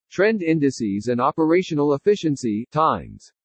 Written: TREND INDICES AND OPERATIONAL EFFICIENCY TIMES

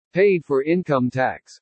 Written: PAID FOR INCOME TAX

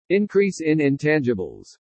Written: INCREASE IN INTANGIBLES

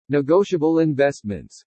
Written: NEGOTIABLE INVESTMENTS

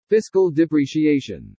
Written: FISCAL DEPRECIATION

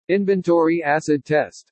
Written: INVENTORY ACID TEST

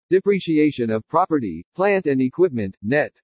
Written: DEPRECIATION OF PROPERTY PLANT AND EQUIPMENT NET

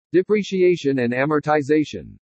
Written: DEPRECIATION AND AMORTIZATION

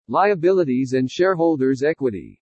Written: LIABILITIES AND SHAREHOLDERS' EQUITY

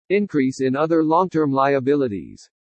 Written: INCREASE IN OTHER LONG-TERM LIABILITIES

